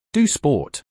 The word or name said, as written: Do sport